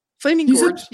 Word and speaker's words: Flaming Gorge: Flaming Gorge. You said it. Flaming you said Gorge. It right.